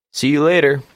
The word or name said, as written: see you